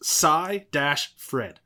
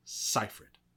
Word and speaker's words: Seyfried: It's pronounced Cy-Fred. Seyfried. – Okay, Seyfried. Okay.